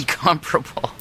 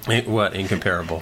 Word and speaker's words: Incomparable: Incomparable. – Incomparable. – You got the emphasis on the wrong syllable.